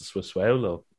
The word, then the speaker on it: Sassuolo: Yeah, and it's weird because Juventus lost to Sassuolo midweek as well. So doing great. Well, you pronounced that well.